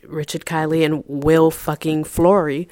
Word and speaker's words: Fleury: Another one, another one who sunned me and, and, and told me how to pronounce his name. His name is not yes. – Will Fleury. It's Will fucking Fleury.